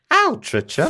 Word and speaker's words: Altrincham: Altrincham would be pronounced differently because it's English. Altrincham.